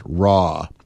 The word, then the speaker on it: Ra: Friday night at 7 with DJ Ra, Rise Against Hate, pronounced Raw.